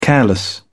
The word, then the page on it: careless: British English